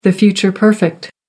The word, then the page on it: future perfect: American English